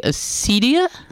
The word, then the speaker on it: acedia: We usually pronounced our C's in Latin as soft C's like Caesar. So I think it would be acedia, but I'm not sure.